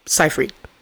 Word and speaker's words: Seyfried: who is played by Amanda Seyfried. Seyfried. I can never say her name right. But Seyfried. Right? – Yes.